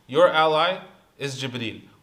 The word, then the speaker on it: Jibreel: And by the way, Jibreel has 6 different pronunciations. Jibreel, Jibra'il, Jibreen. There's different ways that it's pronounced.